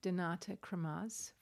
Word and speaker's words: Donata Kramarz: Because our special guest right now is Donata Kramatz from Nosoyo.